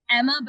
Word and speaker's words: Emma: So my Twitter handle is Emma, but it's spelled with three M.